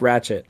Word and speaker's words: Ratchette: Also, it is spelled R-A-T-C-H-E-T-T-E. How y'all pronounce that? – Ratchet. – Ratchet, yeah.